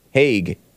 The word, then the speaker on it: Hague: Is that how you pronounce it? The Hague? Hague. In the Netherlands.